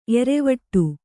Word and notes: ಏರುವಡೆ: ēruvaḍe

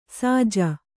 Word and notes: ಸಜ್ಜು: sajju

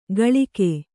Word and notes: ಕಳಿಕೆ: kaḷike

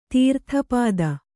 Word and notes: ತೀರ್ಥಪಾದ: tīrtha pāda

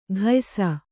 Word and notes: ಘಯ್ಸ: Kannada

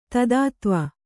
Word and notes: ದೂತತ್ವ: Kannada

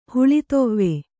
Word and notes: ಹುಲಿದೇವ: huli dēva

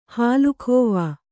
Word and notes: ಹಾಲುಖೋವಾ: hālu khōvā

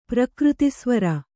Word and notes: ಪ್ರಕೃತಿಸ್ವರ: prakřti svara

